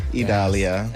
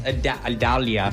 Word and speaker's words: Idalia: Idalia. – Idalia. – Idalia.